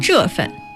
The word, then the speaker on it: 这份: And then 这份, is that pronounced correctly? 这份? – 这份.